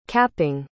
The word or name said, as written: capping